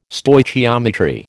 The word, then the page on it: Stoichiometry: American English